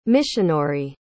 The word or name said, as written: michinori